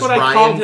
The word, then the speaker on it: Rian: His name is Ryan, but it's spelled R-I-A-N.